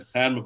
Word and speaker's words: Khair: Akhair Mubarak is K-H-A-I-R. There's not really an English language equivalent of K-H, so it's Khair.